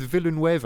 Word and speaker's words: Villeneuve: That's how I remembered how to pronounce it. – Yeah. – I was going with Villeneuve at first, but that's like the Spanish version.